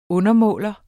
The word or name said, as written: undermåler